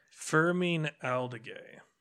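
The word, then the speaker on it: Fermín Aldeguer: Fermin Aldeguer.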